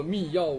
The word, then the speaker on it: 密钥: 反正开源世界里面，就反正是这样的，有一个百度百科的，虽然不太可信啊，但是他说那个，那个密钥就是秘密的钥匙的简称，故读作密钥无误。